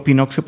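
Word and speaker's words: Pinoxxio: Digo Pinoxio porque se escribe con dos X en medio, supongo que en plan siglo XXI, ¿no?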